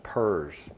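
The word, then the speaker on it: PRRS: PERS, is sometimes the pronunciation of the acronym.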